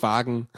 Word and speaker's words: Wagn: da spricht man Deutsch aus Wagen. Wagen. W-A-G-N.